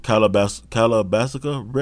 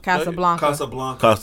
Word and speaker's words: Casablanca: Casablanca.